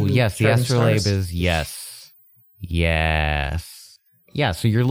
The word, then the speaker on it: astrolabe: Astrolabe.